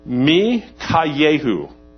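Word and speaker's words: Mikayehu (מיכיהו): Mikayehu. That is the Hebrew pronunciation of this name. I'm going to call him Micah because I'm English and I can't say that.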